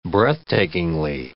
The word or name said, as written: breathtakingly